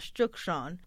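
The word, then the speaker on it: Cuk Ṣon: For those who don't know, the name Tucson is actually a Thaana Autumn name, correctly pronounced as Chukchon, which means Black Hill.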